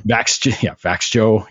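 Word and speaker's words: Växjö: I forget how to pronounce it. I know Vaxel. Vaxjoe. Yes. But it's,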